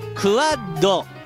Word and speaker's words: QUAD: QUAD と書いてクワッド。